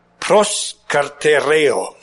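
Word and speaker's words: Proskartereo (προσκαρτερέω): I pronounce Greek with a Spanish accent. Proskartereo.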